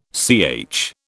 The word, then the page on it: CH: British English